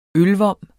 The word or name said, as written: ølvom